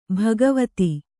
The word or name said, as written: ಭಾಗವತ